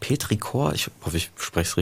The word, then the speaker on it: Petrichor: Ja, Petrichor, doch. – Petrichor, ja.